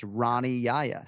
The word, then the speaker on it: Yahya: He does not go by Hani. He has Americanized it. He says Rani Yaya.